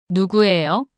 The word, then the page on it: 누구예요?: korean